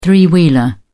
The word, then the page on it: three wheeler: British English